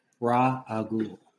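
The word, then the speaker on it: Ra's al Ghul: Oh, are you going for the Raz versus Raj thing? Rajagul? – It's Ra-agul.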